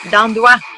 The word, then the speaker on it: Dandois: Dandois.